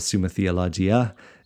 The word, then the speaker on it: Summa Theologica: And Thomas Aquinas is obviously extremely voluminous and has written a ton, but a lot of it is contained in that Summa Theologica or Summa Theologia, as it's someone has just recently told me it's better pronounced that way.